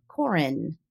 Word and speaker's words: Karen: She has an older sister whose name is spelled K-A-R-E-N, as in Karen, but they pronounced it Corin the entire time.